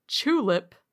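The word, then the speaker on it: Chulip: And I have selected a little game called Tulip, spelled like tulip, but with a CH at the start.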